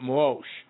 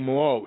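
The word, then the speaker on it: Muoseh: It almost sounds like the Michelin Tires, but I'm calling it Moshe, like King Moshe.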